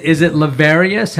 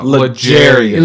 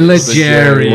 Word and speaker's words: L'Jarius: And is it Lavarius? How do you say? – No, L'Jarius. – L'Jarius. Like Jerry. Sneed.